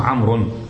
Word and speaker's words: عمرو: هذه فقط الواو يتميز عمرو عن عمر. لا تلفظ. ما تقول عمرو، ما في شيء عمرو، وإن هي إيش؟ عمرون، عمران، عمرين، آخر الكلمة حرف الراء، والواو هاي فقط علامة لتميز عمر عن عمر.